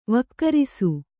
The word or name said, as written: ವಿಕರಿಸು